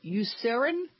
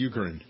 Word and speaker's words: Eucerin: Eucerin? Is that how you pronounce it? – No, it's Eucerin.